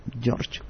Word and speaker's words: Georg: ¿Quién es primero don George? ¿Cómo se pronuncia así en alemán? – Georg. – ¿Así se pronuncia? – Así se pronuncia.